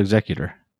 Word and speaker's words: Executor: Darth Vader's Superstar Destroyer, which is correctly pronounced the Executor.